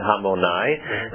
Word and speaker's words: Hamonai: So you notice the way I pronounced the last word? I said Hamonai. The reason is because I'm not really singing these Zemiros now. I'm just sharing with you. And this pronunciation of Hamonai is the way Sephardim do it.